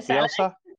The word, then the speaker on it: Bielsa: I can't pronounce his name. – Bielsa, like that's the one.